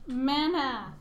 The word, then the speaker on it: Manath: Math? – Manath. – Spell it. – M-A-N-A-T-H. – I'm just gonna spell it how I'm gonna pronounce it. – Manath. – Yeah. – Mayonnaise. – Man and ath. Yeah, Manath. – Okay, so Sebastian, what's your character name? – Crux, K-R-U-X.